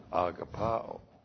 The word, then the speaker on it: agapao: agapao. A-G-A-P-A, long O, agapao.